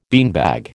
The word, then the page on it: beanbag: American English